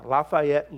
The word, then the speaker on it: Lafayette: I was flying into Lafayette, that's how they say it in Louisiana, Lafayette, Louisiana, recently.